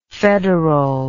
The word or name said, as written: federalness